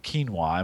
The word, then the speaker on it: quinoa: And so if you haven't seen those yet, um, we did some clean cooking around sweet and sour chicken with quinoa. Am I pronouncing that right? Quinoa. – Quinoa, absolutely.